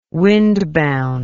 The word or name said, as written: windbound